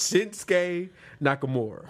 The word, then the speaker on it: Shinsuke Nakamura: Shinsuke Nakamura.